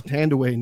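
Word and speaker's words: Thandiwe Newton: But her actual name is Tandaway Newton, which is how she's once people like, you know, Chiwetel Ejiofor started getting big.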